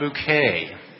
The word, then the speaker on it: Bucket: Her name is spelled B-U-C-K-E-T, but she pronounces it not bucket, but bouquet.